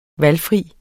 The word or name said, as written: VALGFRIT